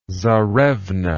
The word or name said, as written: czarevna